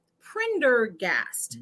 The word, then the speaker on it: Prendergast: Orlando Pendergast. And I think I said that right. Is that Prendergast? – There is the second time.